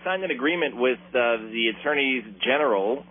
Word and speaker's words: attorneys general: I like saying it that way, which is the proper way to say it. Attorneys general of most of the United States of America. I heard somebody today say attorney generals, and they're not generals.